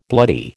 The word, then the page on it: bloody: American English